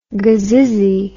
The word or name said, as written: gazizzey